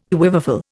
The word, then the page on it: quiverful: Indian